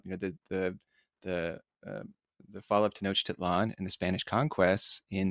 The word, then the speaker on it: Tenochtitlan: Before we get started, is Tenochtitlan. That's how we pronounce that, correct? – Well, actually, nobody knows exactly how it was pronounced, so don't worry about it. And of course, Tenochtitlan is quite good.